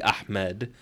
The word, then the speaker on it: Ahmad: Ed is in the end of my name phonetically, Ahmed.